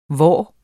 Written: hvor

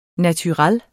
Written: naturel

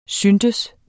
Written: synes

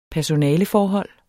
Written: personaleforhold

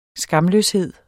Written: skamløshed

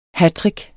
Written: hattrick

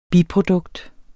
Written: biprodukt

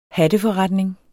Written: hatteforretning

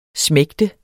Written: smægtende

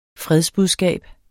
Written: fredsbudskab

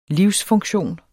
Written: livsfunktion

